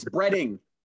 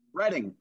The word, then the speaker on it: Bretting: And so Chris Bretling, am I saying that right? It's Bretting. – Bretting. – I for whatever reason, I want to put an L in there all the time.